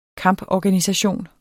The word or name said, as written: kamporganisation